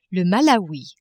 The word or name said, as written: Malawi